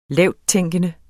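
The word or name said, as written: LAVTTÆNKENDE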